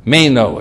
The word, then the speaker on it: meno: So the Greek word here, just give you its transliteration, it's called meno. Meno, M-E-N-O, we pronounce it meno.